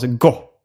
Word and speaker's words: Go: att grundaren till Go tänkte att det skulle vara uttalas på samma sätt som gott, fast utan T.